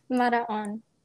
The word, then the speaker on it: Maraon: Maraon.